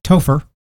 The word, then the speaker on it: Topher: Topher. – Spell it. – T-O-P-H-E-R.